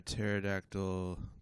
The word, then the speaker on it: pterodactyl: Why can't you hear a pterodactyl going to the bathroom? – Why? – Because the pee is silent. Get it? – Yeah, because it's spelled like P-T or something.